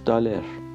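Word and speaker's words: dollar: آنچه ما میگوییم دلار آنچه آنان میگویند دلار آنچه میتوان گفت دالر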